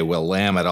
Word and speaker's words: Willamette: since I'll have to say Willamette a lot when we get to the early history of the Pacific Northwest.